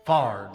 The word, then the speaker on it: Fired: And we say I guess the easiest way we can tell you how to pronounce it is if you spell it FAR'D. Fard.